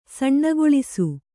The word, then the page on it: ಸಣ್ಣಗೊಳಿಸು: kannada